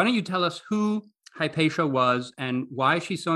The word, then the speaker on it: Hypatia: In Greek, I would call her Ipatia. In the American English I'm used to, she's more often called Hypatia.